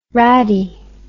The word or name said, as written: raddie